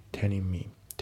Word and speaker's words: telling: であと発音ですけど、 telling の G が抜けますね。 telling me、 telling me じゃなくて telling me。